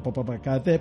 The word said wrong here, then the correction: Popocatépetl: Live, hay livecamp, Popocatépetl y hay Livecam igual porque a,